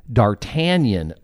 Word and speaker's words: D'Artagnan: And as you know, the D'Artagnan Award recipient, and I believe like 1972, the first one. And I think I pronounced the award correctly. – You did.